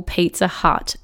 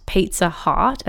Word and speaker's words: Pizza Hut: Gosh, I can really relate to this because I used to call Pizza Hut Pizza Heart, as in H-E-A-R-T. And I think I was 12 when I realized that I had been mispronouncing it the whole time.